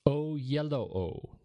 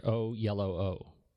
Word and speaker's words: Oyelowo: Oyelowo. That's how they phonetically pronounce it there. Oyelowo. – Mm. – Fancy that. I've been doing it wrong.